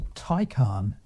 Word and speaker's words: Taycan: I might have said a Tay, but it's not that, is it? – No, it's a Taycan. – T-A-Y-S-N.